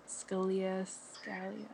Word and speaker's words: Scalia: Am I saying that right? Scalia. – Yes.